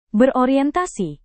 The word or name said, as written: berorientasi